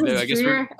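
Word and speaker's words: Driedger: So it is Dreiger. Okay. – I guess we're just ignoring the D in the middle of the name. – The silent D.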